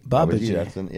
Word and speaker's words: Babaji: Babaji. – Yeah, and that's how it's pronounced too.